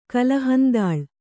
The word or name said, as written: ಕಲಹಂದಾಳ್